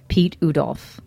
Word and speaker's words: Piet Oudolf: Pete Udolf, which is spelled P-I-E-T-O-U-D-O-L-F.